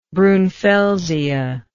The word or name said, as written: brunfelsia